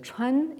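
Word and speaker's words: chuan (川): qui, pris isolément, se prononcent chuan et qui désignent un cours d'eau.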